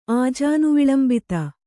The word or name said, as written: ಆಜಾನುವಿಳಂಬಿತ